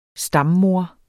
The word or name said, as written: stammoder